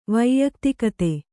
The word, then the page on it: ವ್ಯಕ್ತಿಗತ: vyaktigata